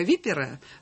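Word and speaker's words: Виппера: история со ссылкой на историка Випера. Правильно я ставлю ударение? – Да.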